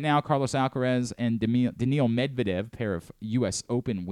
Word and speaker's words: Daniil: How do you pronounce Medvedev's first name? – Daniil. – Daniil. – Daniil Medvedev. – I always thought it was Daniel.